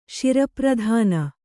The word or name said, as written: ಶಿರಪ್ರಧಾನ